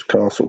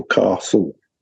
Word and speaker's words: castle: And I kept putting an R in it because the way, if you're brought up in the south of England, you pronounce castle castle. So I ended up spelling it like that. My wife, who's a good northerner, will say castle. And she's quite right to call it castle. because then you don't misspell it.